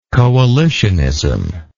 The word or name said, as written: coalitionism